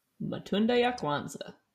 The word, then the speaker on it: Matunda ya Kwanzaa: Matunda ya Kwanzaa. – God, I'm so white. Which means... – That's pretty good. Swahili is pronounced pretty much how it's spelled.